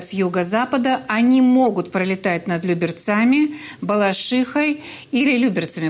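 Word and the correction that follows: Люберцы: Простите меня, пожалуйста, за это ударение. Люберцами, конечно, Балашихой или восточными районами Москвы.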